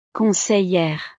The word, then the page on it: conseillere: frances